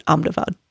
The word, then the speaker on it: Ahmedabad: It is spelled like Ahmedabad, but they pronounce it Ahmedabad.